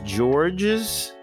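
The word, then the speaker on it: Georges: Your name is George's Chomchom. – Chomchom. – Say it's that again, please. – It's George with a silent S, and you're going to learn something new today.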